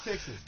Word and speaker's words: Techsys: Texas. It's spelled TECHSYS. But I think it's intentional that they call it Texas.